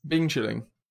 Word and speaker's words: Bing chilling: Bean chilling. Am I pronouncing it wrong? – You pronounce it right, but like I don't know why.